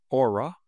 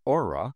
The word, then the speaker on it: Oura: The title, Aura, reader's note, that is spelled O-U-R-A, Aura, and Duncan, get ready to join IPO Bonanza.